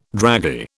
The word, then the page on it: draggy: British English